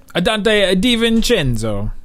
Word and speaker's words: DiVincenzo: Is that how you say it? Cinzo? – Dante DiVincenzo.